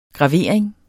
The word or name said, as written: gravering